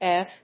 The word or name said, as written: evc